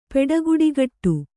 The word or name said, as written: ಪೆಡಗುಡಿಗಟ್ಟು